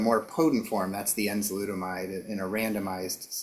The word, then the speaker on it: enzalutamide: And I know that you were involved in a study of a treatment using a combination, I'm going to mispronounce this, of enzalutamide with androgen deprivation therapy. You say it correctly for me. – Enzalutamide. – Okay, I was close.